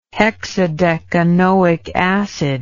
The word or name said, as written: hexadecanoic acid